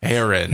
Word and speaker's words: Heron: Heron. – Heron. And it's spelled like H-E-R-N, like the bird.